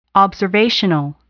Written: observational

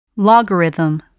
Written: logarithm

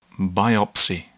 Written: biopsy

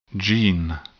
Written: gene

